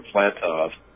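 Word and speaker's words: Flatow: So when I found that name, the reason I'm saying Alfred Flattog, you say Flatto, is because a W is pronounced as a V in German.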